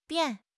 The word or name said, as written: bian4